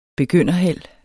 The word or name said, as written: begynderheld